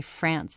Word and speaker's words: France: Of course she doesn't say France, so it rhymes with dance like a normal English-speaking human being.